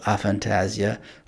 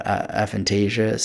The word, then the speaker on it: aphantasia: And as a preview of something that I've got going on coming up for you, I'm doing some research on something called aphantasia, or aphantasia, some people pronounce it. There's different pronunciations.